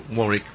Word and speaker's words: Warwick: W-A-R-W-S, yeah, but the... I guess the second W, Warwick, we say, yeah, is quiet.